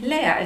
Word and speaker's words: leer: Sí, ahora me vas a decir, André, pero nada en alemán es Lea, excepto un nombre propio en español. Nada en español es Lea, solo un nombre propio. Pero lo que pasa con este falso amigo es que se escribe leer. L-E-E-R. Leer. Y cuando nosotros nos topamos con eso, sobre todo leyendo, uno cree que quiere decir leer, ¿no? Y resulta que lea significa vacío.